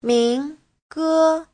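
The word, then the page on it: 民歌: min2ge1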